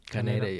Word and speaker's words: Kaneda: Kaneda.